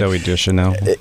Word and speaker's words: Deschanel: Zooey Deschanel, or how do you pronounce her last name? – Zooey Deschanel.